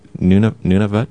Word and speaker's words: Nunavut: I did want to ask you about Nunavut. Am mm-hmm. I pronouncing that right? Nunavut? – Mm-hmm.